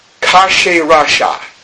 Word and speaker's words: Khshayarsha: If I can pronounce it correctly, it sounds like this. Kasherasha. Here's how it's spelled. K-H-S-H-A-Y-A-R-S-H-A.